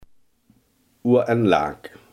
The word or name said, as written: Ohé en Laak